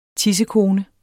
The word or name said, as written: tissekone